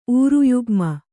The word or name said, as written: ಊರುಯುಗ್ಮ